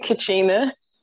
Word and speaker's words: Kachina: So you said Kachina, right? Am I saying it right? Because he be, you know, rolling it together sometimes like he from the South. – Kachina.